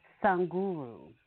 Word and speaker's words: Sadhguru: His name is Sanguru. It's spelled S-A-D-H-G-U-R-U.